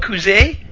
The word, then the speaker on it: Cousy: So we mispronounced Bob's last name all these years. It's Bob Cousy.